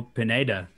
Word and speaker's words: Pineda: But this makes me really interested in Daniel Pineda, if I'm saying it correctly.